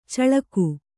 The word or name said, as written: ಚಿಲಕೆ